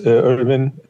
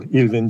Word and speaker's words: Irvin: So my name is Irvin, or Ilvin Cemilcik in Turkish pronunciation.